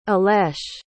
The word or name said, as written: ilesh